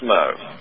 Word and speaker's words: Meaux: It's spelled M-E-A-U-X or something like that, is that right? – That is correct. And I believe it's pronounced Mo.